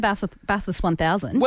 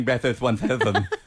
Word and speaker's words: Bathurst 1000: Well, you missed the Bathless 1000.